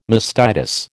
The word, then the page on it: mastitis: American English